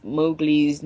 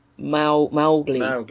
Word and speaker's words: Mowgli: According to Kipling's daughter, the first syllable of Mowgli's name rhymes with cow rather than show. So it's Mowgli.